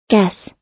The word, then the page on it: guess: American English